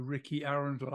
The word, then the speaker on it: Rikki Arundel: Okay, it turns out when I changed my name to Ricky Arundel, I didn't know at the time that I was going to change to a completely unique name.